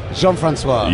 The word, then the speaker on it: Jean-François: Jean-François. – Oh, I could have told you that because it's French. – Oh, I was trying to be Jean-François. – Jean-François. – Yes, perfect.